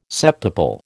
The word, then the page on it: septuple: American English